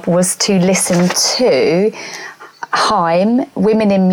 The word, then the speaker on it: Haim: Yeah, I've just seen another one that says Haim rhymes with time.